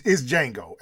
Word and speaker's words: Django: It's not Drango. – It's Django, ain't it? – Yeah. – The D is silent, ain't it? – Yeah, it's just Django. – I really butchered that, didn't I? – He's about to kill me! – I pronounced the D and put an R in there.